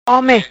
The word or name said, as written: Home